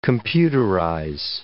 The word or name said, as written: computerize